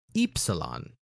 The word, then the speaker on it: Ypsilon: if you're saying it like in English, it is Upsilon, not Upsilon, but if you're saying it in Greek, it's actually Ypsilon.